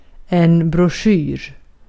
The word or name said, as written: broschyr